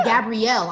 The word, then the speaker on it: Gabriel: Are we still feeling Gabriel? Gabrielle. I'm saying his name wrong.